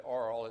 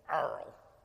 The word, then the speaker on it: Arles: Arles is how they say it in France. You don't say Arles, it's Arles.